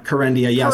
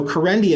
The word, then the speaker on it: Kerendia: Kerendia. Yeah. – Kerendia. – So we have. – Okay. – So Kerendia is approved,